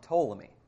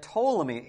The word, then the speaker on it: Ptolemy: Now, the way I remember this, like which is which, is that Ptolemy, even though it's not pronounced this way, but Ptolemy starts with a P, and so does Pharaoh.